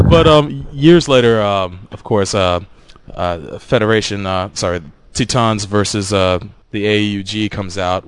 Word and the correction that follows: Titans: Titans. – Oh, I'm sorry. I slipped into the Japanese pronunciation of it. Titans versus AUG.